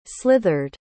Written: slithered

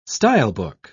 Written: stylebook

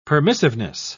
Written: permissiveness

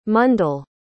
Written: mundal

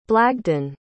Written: blagden

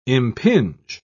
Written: impinge

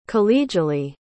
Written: collegially

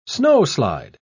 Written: snowslide

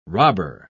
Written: robber

